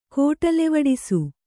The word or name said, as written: ಕೋಟಲೆವಡಿಸು